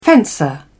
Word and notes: fencer: British English

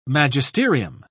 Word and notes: magisterium: British English

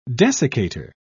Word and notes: desiccator: British English

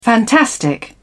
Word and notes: fantastic: British English

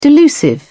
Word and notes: delusive: British English

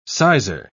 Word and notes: sizar: British English

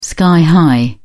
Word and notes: sky high: British English

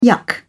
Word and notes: yuck: British English

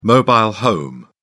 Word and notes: mobile home: British English

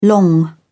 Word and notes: long: British English

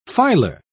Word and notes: filar: British English

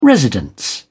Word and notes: residence: British English

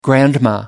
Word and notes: grandma: American English

